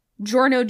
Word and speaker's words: Giorno: You have Giorno Giovanna, which Italian doesn't have the J sound, but Gio is would essentially be pronounced JoJo anyway.